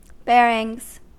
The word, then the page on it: bearings: American English